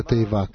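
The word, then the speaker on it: тейва (תיבה): При этом конечная буква «гей» не произносится в слове «тейва» — «ковчег».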